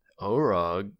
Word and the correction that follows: Org: Wrong, you already fucked it up. – Is that how you pronounce it? – It's Org. – Org. – It's like orc, but with a G.